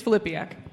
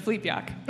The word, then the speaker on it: Filipiak: It's Filipiak. – Filipiak. Okay. With the accent on the second syllable. Right. Flipiak. – Unless you're Polish, then it's Flipiak.